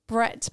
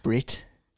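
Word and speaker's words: Bret: We went with Brett McKenzie, which was Leo's choice. – It's pronounced Brit.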